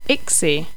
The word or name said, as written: ixi